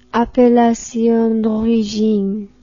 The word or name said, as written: appellation d'origine